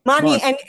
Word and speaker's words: Monty: Monty, and if it's helpful, it's like Bonnie and Donnie and Ronnie, yeah, spelled the same way as those things. Right. I just have an M in the beginning of it.